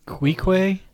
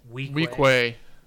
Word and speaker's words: Weequay: Queequay? – Weequay.